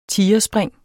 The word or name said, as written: tigerspring